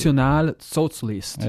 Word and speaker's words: Nationalsozialist: It's really, if you pronounce the word national as it is in German, it's Nationalsozialist.